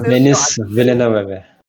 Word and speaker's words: Denis Villeneuve: And he's like, also, fun fact that is the day I realized that you pronounce Denis Villeneuve that way. – Yeah, me too. – Denis Villeneuve.